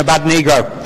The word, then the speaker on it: Abednego: นะคะส่วนอาจารย์วอนนะคะตอนที่ท่านพูดถึงดาเนียลชัดรักมีช้างอเปตเนโกนะคะอาจารย์วอนนี่ท่านเป็นศิษยาภิบาลผิวดำนะคะ The correct pronunciation of those words are Shadrach, Meshach, and Abednego.